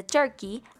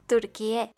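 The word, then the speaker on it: Türkiye: Pero tranqui, que en realidad solo aplica para el idioma inglés, que pasará de Turkey al original, Turquie, que se pronuncia casi igual, solo hay que agregarle un Y al final.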